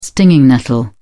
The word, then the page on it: stinging nettle: British English